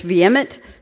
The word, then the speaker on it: vehement: So I had to look it up in the dictionary, and the H is silent, so it's vehement desire.